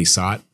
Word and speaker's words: Sat: And I used to play the heck out of that game with my buddy Sot. Shout out Sot. – His name is Sot. How do you spell it? – S-A-T. Satyam Vakil. – Well, his name's Sat. So. – No, it's not. – S-A-T.